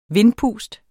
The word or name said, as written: vindpust